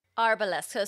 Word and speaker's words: Arboles: It's trees in Spanish, which is pronounced, the Spanish pronunciation is Arboles. So the accent's on the A. So Arboles, but some people say Arboles. I don't really care either way, but like if we're being...